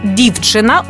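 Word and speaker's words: dziewczyna: В польській мові зазвичай ми наголошуємо передостанній склад. Ну, наприклад, слово «дівчина» українською, а польською... – «Дівчина». – Чуємо наголос на передостанній склад.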